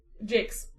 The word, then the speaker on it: Jacques: Jacques. – There is no long E. – You're gonna make Jakes. We're going to call him Jakes.